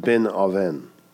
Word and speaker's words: Benoni: she names her son Benoni. It's pronounced Ben-Oven. That's how it's pronounced in the Hebrew.